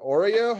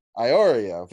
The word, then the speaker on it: Iorio: Vincent, is it Oreo? How do you pronounce that? – Iorio.